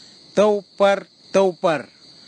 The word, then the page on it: taupar taupar: kwamera; Tanna